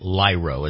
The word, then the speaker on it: Lyro: Yeah, it is Lyro. It's spelled L-Y-R-O. Lyro.